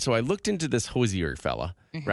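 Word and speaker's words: Hozier: Okay, so my girlfriend, she loves Hosier? Is that how you say it? – Hosier. – Yeah. – Hosier.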